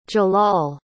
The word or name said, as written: jalal